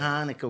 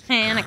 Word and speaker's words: Hanukkah: It's to not say Hanukkah with an H. It's Hanukkah. Hanukkah. You have a glass of milk before you say it. – Okay. – Yeah. Hanukkah, a good not- sound.